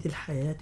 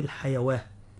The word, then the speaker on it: الحياة: كذلك كلمة الحياة الدنيا، كلمة الحياة الألف رسمت فوق الواو، لكن ننطقها ألف لا ننطقها واو، لا نقول الحيواة، تمام.